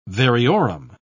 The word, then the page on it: variorum: British English